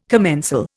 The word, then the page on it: commensal: Indian